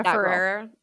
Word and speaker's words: Ferrera: America Ferrera.